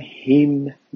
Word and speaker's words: HYMM: اسم این رو گذاشتم هیم ملودیز، HYMM ملودیز، HYMM هیم. واژه هیم با این تلفظ دو معنی میتونه داشته باشه و دو جور نوشته میشه،